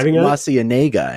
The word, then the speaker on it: La Cienega: It was La Cienega Boulevard, right? – And then he's like, no, it's La Cienega. And I'm like, that's not what the Proud Family taught me.